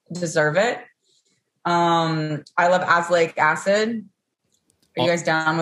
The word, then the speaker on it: azelaic: It's better with azelaic. – Azelaic. – Azelaic. – Emphasis on the ass.